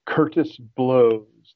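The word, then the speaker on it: Curtis Bloes: Okay, so my last name is spelled like blows, but not B-L-O-W-S. It's B-L-O-E-S. Curtis Blows, like that.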